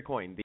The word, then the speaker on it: Coyne: Yeah, Jerry Coyne. Did I say that right? – Yeah.